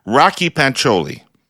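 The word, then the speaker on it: Rakhi Pancholi: Second, the Alberta NDP leadership candidate's name is properly pronounced Rocky Pancholi. Have I got it? – You did it.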